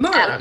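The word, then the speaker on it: Maher: it's M A H E R, and I feel like that's probably Mar, but Mar, yeah.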